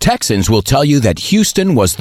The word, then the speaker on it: Houston: La ciudad Houston se pronuncia Houston.